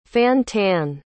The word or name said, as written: fan-tan